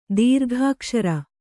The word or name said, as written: ದೀರ್ಘಾಕ್ಷರ